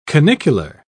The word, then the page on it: canicular: British English